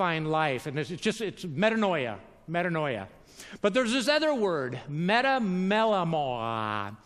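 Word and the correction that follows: Metamelomai (μεταμέλομαι): I pronounced it. Metamelomai. Metamelomai. Yeah, close enough.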